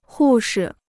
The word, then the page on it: 护士: hù shi